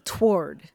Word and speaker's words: toward: It's two syllables, not one. – You know, I think I grew up saying it one syllable toward.